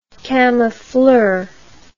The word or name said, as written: camoufleur